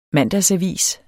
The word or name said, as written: mandagsavis